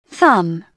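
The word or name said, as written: thumb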